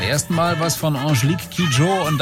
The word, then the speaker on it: Kidjo: Klingt einfach, je nachdem, wie man nuschelt, so ähnlich. Angelique Kid Joe.